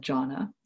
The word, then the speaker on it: jhana: But there are also some distinct states of strong samadhi that are called jhana, and that's J-H long A-N-A. And they are also, it's translated into English, it's sometimes translated as absorption or immersion, or it's just left untranslated as jhana.